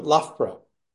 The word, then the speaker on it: Loughborough: Loughborough? No. – Pretty good. Loughborough.